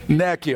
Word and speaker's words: NICU: Ann keeps telling me I'm saying it wrong. NICU.